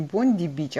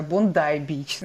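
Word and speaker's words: Bondi: на самом деле это произносится не Бонди-бич, а Бондай-бич, знаешь, вот как у нас тут.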